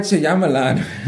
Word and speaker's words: Shyamalan: Night Shyamalan. – That's how you say his name. – Shyamalan. – And Shyamalan. – Shyamalan.